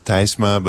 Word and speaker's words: Tysma: It's T-Y-S-M-A. So you would pronounce it in Dutch, you would pronounce Tysma, but in English it's usually Tysma.